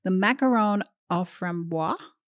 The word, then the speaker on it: macaron au framboise: Can you please say that again? Because I've been saying it so wrong, and I actually love hearing you say it properly.